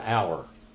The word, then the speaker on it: hour: We don't pronounce the H in our word hour. It's not hour.